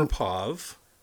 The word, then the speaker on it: Andropov: Andropov. It's a Russian guy, Andropov.